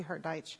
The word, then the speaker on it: Hart-Deitch: My name is Sally Hart-Deitch. I am a registered nurse. I've been a nurse for 20 years. Ha! Her surname is Hart-Deitch. Like, did you see how I butchered her surname?